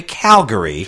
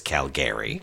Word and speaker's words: Calgary: So these kids live in Calgary, which is pronounced, by the way, Calgary. – Calgary. – I told you I talked to an actual Canadian, and she told me no one says Calgary.